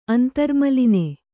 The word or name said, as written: ಅಂತರ್ಮಲಿನೆ